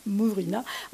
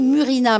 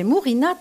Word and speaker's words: Murina: Mais comment tu prononces bien, toi, dis donc, Damien, Mourina. Moi, j'aurais dit Murina, mais c'est Mourina, t'as raison, puis c'est vachement plus joli à prononcer.